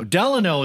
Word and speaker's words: Delano: Franklin Delano. Delano is the pronunciation.